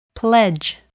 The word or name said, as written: pledge